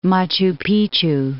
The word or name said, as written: Machu Picchu